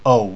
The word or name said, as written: ou